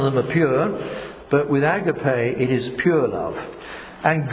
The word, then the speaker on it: agape: If you don't know Greek, and I don't, but you pronounce it agape, A-G-A-P-E, which is God's love, the pure love from God.